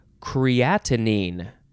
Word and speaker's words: creatinine: Okay, the next word is creatinine. So we added a syllable. We had creatine, and this is creatinine, C-R-E-A-T-I-N-I-N-E.